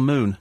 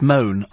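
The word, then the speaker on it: Mone: It's Michelle Moon. No, it's Moan, I'm afraid. Seeing as I spent an hour in her company, I think I know how to pronounce her name. It's Moan.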